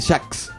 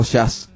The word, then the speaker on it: Jazz: El pájaro tocaba el saxofón, digo tocaba porque ha fallecido, y se llamaba Charlie Parker, un saxofonista de Jax, como dicen algunos, o Jazz, como dicen los que pronuncian bien.